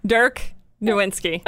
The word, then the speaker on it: Dirk Nowitzki: Did I? I never pronounce it right. Did I get it right? Dirk. You say his name. – Dirk Nowitzki. That's what. So I did it right.